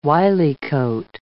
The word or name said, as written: wyliecoat